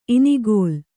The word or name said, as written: ಇನಿಗಲೆ